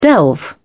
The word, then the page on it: delve: British English